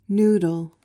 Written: noodle

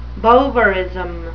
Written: bovarism

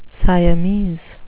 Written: siamese

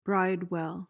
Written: bridewell

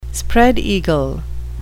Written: spread-eagle

